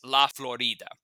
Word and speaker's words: La Florida: And he called the area, the correct pronunciation is La Florida, which is in honor of Pascua Florida, which is Spanish for Feast of the Flowers.